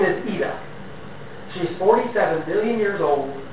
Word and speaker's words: Ida: It's not Ida, it's European, so Ian is spelled I-A-N, this is Ida, I-D-A.